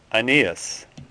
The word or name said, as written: Aeneas